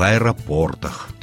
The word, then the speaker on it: аэропортах: Во множественном числе ударение будет всегда на четвертый слог. Аэропорты в аэропортах.